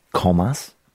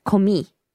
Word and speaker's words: commis: So it's C-O-M-M-I-S. Matt on the Loz line says, fact check, it is pronounced call me.